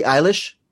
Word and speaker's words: Eilish: Billie Eilish, right? – Yes, correct. Eilish, yes.